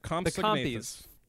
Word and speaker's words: Compsognathus: Yep, Compsognathus.